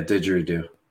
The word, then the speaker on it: didgeridoo: I was in Australia, and obviously, I'm sure you've heard of a didgeridoo, right? I think I'm saying it right. – Yeah, didgeridoo.